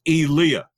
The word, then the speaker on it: Elia: It's always pronounced incorrectly. It's Elia.